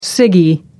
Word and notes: ciggie: American English

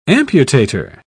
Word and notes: amputator: British English

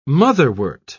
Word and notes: motherwort: British English

